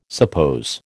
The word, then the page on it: suppose: American English